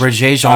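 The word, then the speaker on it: Regé-Jean: Reg Jean uh, Page. Come on, it's French, Paul. – Regé? I don't know. That's, that's not Regé. – it's no absolutely how you pronounce it.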